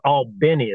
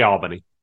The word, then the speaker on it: Albany: Which it's for the locals, it's pronounced Albany. Is that correct? – Yeah, to some around here it is, but we just say Albany.